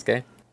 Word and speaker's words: Yasuke: But I think it's Yasuke. – Yeah, it's Yasuke, okay. Okay, yeah, like Sasuke, to be fair.